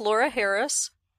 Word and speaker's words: Laura Harris: My name is Laura Harris.